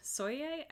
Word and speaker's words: Sobiech: Also coming out on October 16th is a documentary called Clouds, based on the true story of Zach Soye. I think I'm saying that right. That sounded like how he said it, but it's spelled S-O-B-I-E-C-H. So I'm sorry if I'm butchering that.